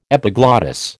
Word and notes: epiglottis: American English

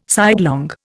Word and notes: sidelong: Indian

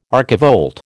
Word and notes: archivolt: American English

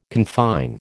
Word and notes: confine: American English

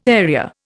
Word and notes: area: Indian

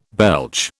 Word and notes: belch: British English

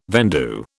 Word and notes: vendue: British English

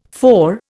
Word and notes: FAO: Indian

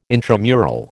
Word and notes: intramural: American English

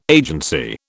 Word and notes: agency: British English

